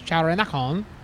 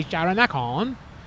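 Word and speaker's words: Charoen Nakhon: So Charoen Nakon is spelled C-H-A-R-O-E-N space N-A-K-H-O-N. So that is what? 11 letters to say Charanakon.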